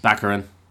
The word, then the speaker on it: Baccarin: Baccarin.